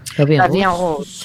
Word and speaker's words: La Vie en Rose: La Vie en Rose, pone, es lo que yo pronunciaría. Rose, ¿cómo se pronunciaría? – La Vie en Rose.